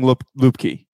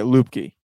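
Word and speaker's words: Luepke: No, the announcers are saying Lipke. Hunter Lipke. – Lipke. – I'm pretty sure. Yeah, Lipke.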